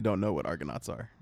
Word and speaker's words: Argonauts: Argonauts, did I say that right? – Yes, you did. – Argonauts.